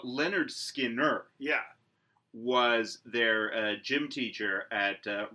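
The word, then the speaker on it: Lynyrd Skynyrd: All right, so the album I'm doing is Skinner's first album, pronounced Leonard Skinner. Which is actually helpful, because as everyone will see looking at the band name, it doesn't look like it's pronounced Leonard Skinner.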